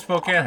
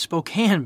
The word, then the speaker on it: Spokane: What does Spokane have to do with this whole mess? – It's Spokane. I don't understand why nobody can pronounce this place.